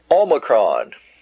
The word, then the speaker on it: Omicron: Omicron, Omicron. Like in ought, ought. Now, this letter is quite often pronounced with just a slight O sound, ah, bot, box, box. Yeah, that's the way, box. But actually, it should be pronounced aw, as in ought.